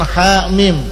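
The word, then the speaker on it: ha mim (حم): Jangan ha mim dibaca hem, hem. Yasin yes.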